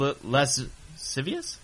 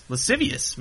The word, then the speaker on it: lascivious: In 2007, a man arrested for lewd and lascivious? Lascivious. Man, that's a good word. – It is a good word. I don't even know what it means. – No. I think that's a silent C there.